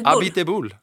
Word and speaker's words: Abiteboul: Cyril Abiteboul.